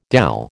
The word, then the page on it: dhal: American English